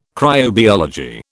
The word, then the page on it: cryobiology: British English